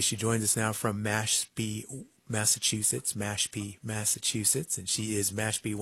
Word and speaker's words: Mashpee: Massipee Wampanoag is the way you would say Mashpee Wampanoag traditionally.